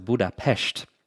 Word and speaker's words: Budapest: We call that, we anglicize it to Budapest, but it wasn't until 1873 that the two were actually merged and united into the big city that we know today. The Hungarians pronounce it Budapest.